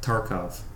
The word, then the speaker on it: Tarkov: How do you pronounce that? – Tarkov. Escape from Tarkov.